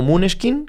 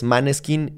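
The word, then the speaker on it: Måneskin: Sin embargo, también tenemos artistas como Muneskin que sí, así se pronuncia, no es Maneskin ni Maniquín, es Muneskin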